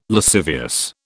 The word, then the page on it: lascivious: British English